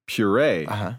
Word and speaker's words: puree: It's pronounced puree, as in hooray.